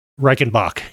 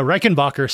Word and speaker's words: Reichenbach: Season one has David Burke for the entire thing, but they end it with Reichenbacher Falls. – Reichenbach. – Yeah. Reichenbach. What am I saying? – I don't know. Reichenbacher sounds pretty cool, though.